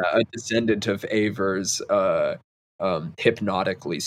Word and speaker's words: Ewers: Ewers? Like that? – Yes, yes. It was actually the first girl I ever dated. That is the correct pronunciation.